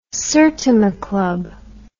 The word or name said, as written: Sertoma Club